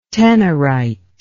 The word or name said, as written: tenorite